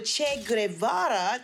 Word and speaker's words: Che Guevara: And when they asked Jane if she regretted not having sex with Che Guevara, did I say it right, Chewie? – Good job.